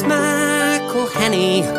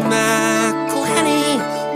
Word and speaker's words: McElhenney: McElhenney. – It's McElhenney. – McElhenney. – McElhenney. – McElhenney. – If there was doubt, now there won't be any. It's McElhenney.